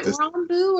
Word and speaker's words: Rambeau: Is it Rambeau or Rambo? – Rambeau, sorry. Okay. Yeah, Rambo. – Saying it wrong this whole time. – No, you're right. That was a mispronunciation on my part, but pardon me there.